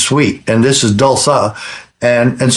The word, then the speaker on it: Dulsa: It's called that Dulsa. It's a sugar substitute. – Spell it. – It's D-U-L-S-A. – Dulsa, D-U-L-S-A, okay?